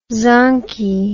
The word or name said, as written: zonkey